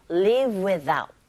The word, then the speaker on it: live without: 그리고 live without 이라고 했는데 이때 v 사운드와 without 연결시켜서 발음하시면 live without. Live without 이런 식으로 발음하시면 됩니다.